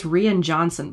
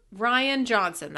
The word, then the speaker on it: Rian Johnson: I thought I heard you pronounce it as Rian Johnson before. – No. – No? Never? Never, No. – ever? Never? – Ryan Johnson, that's how you say it.